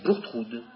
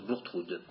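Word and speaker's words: Bourgtheroulde: Méfiez-vous de l'orthographe, hein, mais la prononciation, c'est bien Bourtroude. On prononce Bourtroude.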